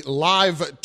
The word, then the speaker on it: LIV: And he will get that from the Live Tour. It's spelled L-I-V, but apparently it's pronounced live.